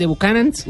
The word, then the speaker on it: Buchanan's: tienes derecho a tres botellas de Buchanan's. – Uy. ¿Lo dije bien? Ah, pa. Sí, sí.